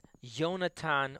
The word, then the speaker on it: Yonatan: In Hebrew, the spelling of the name Jonathan, which is actually pronounced as Yonatan or Ashkenazic Yonasan, is Yud.